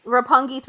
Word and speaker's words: Roppongi: Roppongi 3K? – Roppongi.